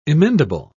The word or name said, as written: emendable